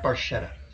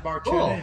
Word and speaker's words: Barchetta: But the true Italian, I believe, pronunciation, yeah. – You can look this up, is Barchetta.